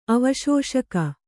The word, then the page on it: ಅವಶೋಷಕ: avaśoṣaka